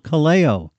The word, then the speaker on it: Kaleo: And we began with a group called Kaleo. That's spelled K-A-L-E-O.